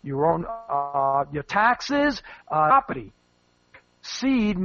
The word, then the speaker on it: Cede: Now, Seed is spelled C-E-D-E, Seed and Company.